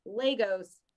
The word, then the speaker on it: Lagos: Wish the MCU would get the pronunciation right. Lagos, not Lagos. Huh, I learned something today.